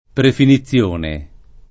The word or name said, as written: prefinizione